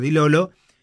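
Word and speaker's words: Di Lollo: Con un ADN Bostero, con Di Lolo, como vi que dijo que hay que pronunciar Di Lolo, y con este pibe Jagger Herrera.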